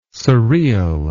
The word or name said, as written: surreal